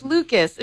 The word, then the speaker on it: Lucas: She would say Lushkis. And he's like, Lori, it's Lucas.